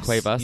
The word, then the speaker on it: Cuevas: Cerundolo has not, but there's Pablo Cervezas? I want to say that wrong. I'm definitely saying that wrong. – Cuevas, yeah.